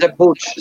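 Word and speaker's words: Żebbuġ: Generalnie źle jeżdżą i tego tutaj za szybko po to to już powoli będziemy zamykać, ale jeszcze wybrałam zdjęcie z Zebuk. Dobrze mówię, czy źle wymawiam? – Zebucz, Zebucz. – Zebucz, okej. Okay.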